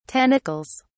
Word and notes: tentacles: British English

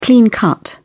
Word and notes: clean-cut: British English

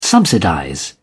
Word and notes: subsidize: British English

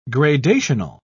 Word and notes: gradational: British English

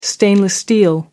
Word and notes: stainless steel: American English

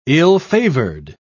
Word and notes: ill-favored: British English

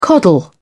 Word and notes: coddle: British English

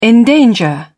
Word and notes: endanger: British English

